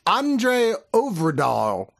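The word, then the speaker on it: André Øvredal: Well, I goddamn looked it up and it actually is kind of phonetic, despite the fact it's got an O with like a slash through it. It's Andre Overdahl. I'm not saying it pretty, but that's how it's actually pronounced.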